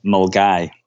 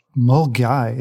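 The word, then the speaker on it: Milngavie: Milligeneve and Fort William. What is it? Correct me. – We've definitely Scottished you here. It's actually pronounced Mulgai. – Mulgai.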